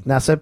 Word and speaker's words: Nassib: Carl Nassib is how you say his last name? – Carl Nassib. – Nassib. – Yes.